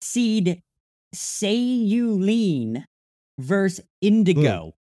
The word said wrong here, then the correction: cerulean: Oh, you mean cerulean? – Cerulean. Maybe that's how you say it.